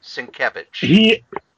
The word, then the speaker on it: Sienkiewicz: Number 2, the variant cover by Bill Sink Sandwich? – Sienkiewicz. – Thank you.